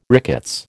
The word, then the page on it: rickets: American English